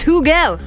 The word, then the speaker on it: Hugel: Some are saying it's Hugel. Apparently, it's Hugel.